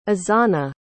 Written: azana

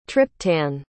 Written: triptan

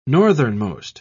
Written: northernmost

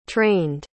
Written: trained

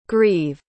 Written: greve

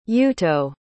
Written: yuuto

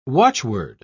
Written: watchword

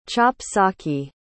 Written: chop-socky

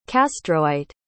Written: castroite

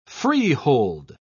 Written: freehold

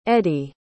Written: eddie

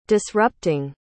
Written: disrupting

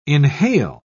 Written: inhale

